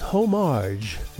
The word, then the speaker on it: homage: Coming up, one of my all-time favorites again, Pogo doing a so-called homage. No, I'm not pronouncing this wrong. It's Homer and Marge from The Simpsons, whose sound samples were used in the making of this song, in typical Pogo style.